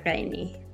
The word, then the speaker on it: Gheini: Even though I butchered your name, uh, bad anthropologist, would you just say your name as it should be pronounced? – Yeah, it's Mozhdeh Gheini.